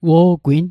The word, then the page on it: wo'gwin: Mi'gmaq